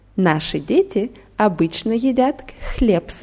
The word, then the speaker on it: наши: Наши Друзья очень хорошо играют в теннис. – Gut, und die Betonung bei наши ist auf A.